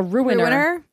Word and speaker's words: Ruiner: and this is a game called Runer. And it Runer? – Is Runer. – Runer. – Like I'm gonna ruin your day. – A ruiner.